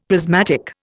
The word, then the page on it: prismatic: Indian